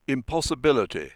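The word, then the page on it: impossibility: British English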